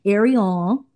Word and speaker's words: Arion: We have Arion. Did I say it right? – Yes, Arion. – You gotta look, put a little French on it, make it sound a little, you know, fancy.